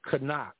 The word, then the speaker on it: knock: Knock, a knock, silent K. Here's the one I love. No. K is silent, wouldn't it be now? N-O-W.